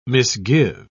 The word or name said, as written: misgive